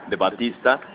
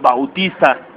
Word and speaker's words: Bautista: Hablando de Luis Mameyes, chéquense, pusimos la foto de Batista. Que curiosamente yo me voy enterando que es Bautista. – Uh-huh. Sí, es Bautista. – Sí, los gringos no saben pronunciarlo. – No, sí, por eso es Bautista.